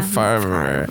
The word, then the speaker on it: Favre: Or you have the Brett Favre situation where it's a weird order of letters, but yet it's pronounced as if, right? Or is it, yeah. It's you kind of are supposed to sort of slur that one. You're supposed to just go Favre.